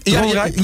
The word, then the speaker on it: Dronrijp: Ja, jullie waren, zeg ik dat goed? Dronrijp? – Ja, jullie waren enigszins in de buurt van de studio.